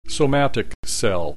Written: somatic cell

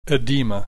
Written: edema

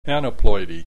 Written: aneuploidy

